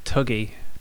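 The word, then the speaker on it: Thuggee: Oh, they're called the thuggy cult. Or I think sometimes they pronounce it tuggy.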